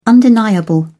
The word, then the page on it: undeniable: British English